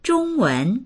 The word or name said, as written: zhong1wen2